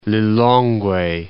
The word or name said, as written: Lilongwe